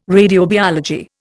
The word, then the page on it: radiobiology: Indian